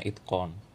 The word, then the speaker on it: Itqan: I T Q A N, tapi bacanya Itcon.